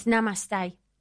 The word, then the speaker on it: Namaste: We will meet in the cocktail bar at the Namaste when you arrive. I don't know how to pronounce that. – It's Namaste.